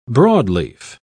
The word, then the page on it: broadleaf: British English